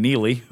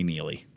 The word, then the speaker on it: Neale: Brian Neely. – Yeah, one of my pet peeves. If I had a dime for every person that calls me Neely, the E is silent.